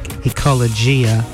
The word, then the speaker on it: Ecologia: That's spelled E-C-O-L-O-G-I-A, like Ecologia, but it's actually pronounced Ecologia Rising, R-I-S-I-N-G.